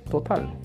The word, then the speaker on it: total: En español se quiere igual, total, total. Pero en inglés la pronunciación es totalmente diferente. Esa segunda T la vamos a pronunciar como una R. Serían los toro, toro, total control.